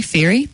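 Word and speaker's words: Fairey: Just search for Julie Ferry, that's spelled F for Fred, A-I-R-E-Y.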